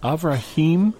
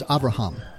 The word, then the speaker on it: Avraham: Is it Avrahim, his new name in Hebrew, Avrahim? – It becomes Avraham.